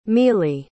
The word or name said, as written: mele